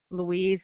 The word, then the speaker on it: Louise: Louise, L-O-U-I-S-E, Thompson, T-H-O-M-S-O-N. – L-O-U-I-S-E for Louise, T-H-O-M-A-S. – No, Thompson without a P, T-H-O-M-S-O-N.